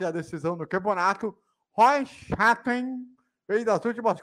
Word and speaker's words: Chastain: O Ross, como é que é o nome da pronúncia? – Ross Chastain. – Chanting, exatamente. – Chastain.